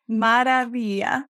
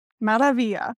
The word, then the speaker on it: Maravilla: Or like, when you roll the R, it kind of sounds like a D. Mara. Yeah. Mara. Okay. Maravilla. Yeah. If you say it like a D, like a soft D, then that's good. – Okay. Maravilla. Maravilla. – There you go. Perfect.